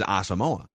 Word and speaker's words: Asamoah: So we know, and people have sent us a clip but the official pronunciation guide by the team is Asamoah.